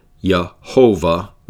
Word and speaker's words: Jehovah: So sometimes it's pronounced Yahweh with just two vowel sounds, and other times Jehovah with three vowel sounds.